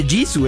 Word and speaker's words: Jisoo: Lisa, Jisoo, que se pronuncia Jisoo, ¿eh? Eso solamente lo sabemos los grandes fans del K-pop.